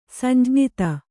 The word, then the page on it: ಸಂಜ್ಞಿತ: samjñita